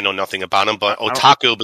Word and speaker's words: Otaku: I'm gonna try to pronounce it, and I'm pretty sure you're gonna do it. Tokyo Otaku, Otaku. – Oh, I said it right.